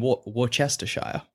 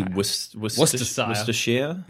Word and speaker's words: Worcestershire: In Worcestershire. Ooh. Which I'm sure I'm pronouncing wrong. – Would that just be Worcestershire? – Worcestershire? – Like the sauce. – Like the sauce, yeah.